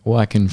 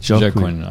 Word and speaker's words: Joaquin: First one, a listener who I also should qualify as my friend said that I was mispronouncing Joaquin Phoenix's name the entire two episodes. – So what were you saying? – Well, I was saying with a J, if I'm being honest.